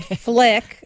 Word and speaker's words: Flic: It's called Flic. That's spelled F-L-I-C, Flic.